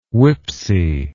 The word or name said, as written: WPPSI